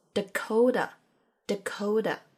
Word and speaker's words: Dakota: 这里比较需要注意的是 Dakota 的发音。首先，它的第一个 a 发的是弱化的 a，很弱很弱的那种。然后这个单词里的 t 发的是接近 d 的音。所以它念作 Dakota，Dakota，北达科他州。